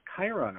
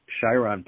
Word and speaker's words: Chiron: I know you said Chiron, but I usually call it it's C H I R O N. Okay. I R O N. But it's really a K sound.